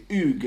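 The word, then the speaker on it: Hugues: which you say the same as Hugh, but in reverse. So all the syllables of Hugh that are silent, you pronounce, and all the ones that are sounded, you silent. So instead of Hugh, it's Oog.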